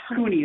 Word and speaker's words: Kuhne: Cooney. It's one of those weird Swiss names where they actually pronounce the E.